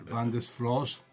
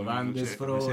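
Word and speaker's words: Van de Sfroos: No, però Van de Sfross. – Vabbè, forse lo pronunciavo male io, Van de Sfross. – Sei romano, quindi... – Van de Sfross. – Van de Sfross.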